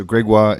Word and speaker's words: Gregoire: All right, so Gregoire is a French name. And I found that living here for a few years, people really struggled pronouncing my name.